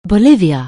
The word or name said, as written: Bolivia